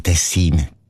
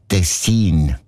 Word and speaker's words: Tessin: Also klar, Tessin. Aber der SRF-1-Hörerin Regula Stern-Grieser von Locarno ist aufgefallen, dass gewisse Leute statt Tessin Tessin sagen. Also Betonung auf mich und mit einem kurzen I.